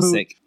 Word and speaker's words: Usyk: Oh, Usyk.